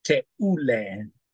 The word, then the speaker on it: Teule: So tell us, what is Teule? – All right. Well, thank you, Pastor Scott. Teule in Swahili means chosen. It's that U that you pronounce U. Teule.